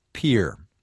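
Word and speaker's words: Pierre: With apologies to all the residents of South Dakota who wrote about our commentary yesterday, the capital of the Mount Rushmore state, that's its actual nickname, by the way, is actually pronounced Pier.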